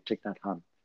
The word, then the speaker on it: Thich Nhat Hanh: Thich Nhat Hanh. I believe yes. that's how it's said here in California, Thich Nhat Hanh.